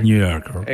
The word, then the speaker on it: New Yorker: ¿Cómo pronunciaste? – De New Yorker. – New Yorker. – Exactamente. Que habla acerca de la radio AM.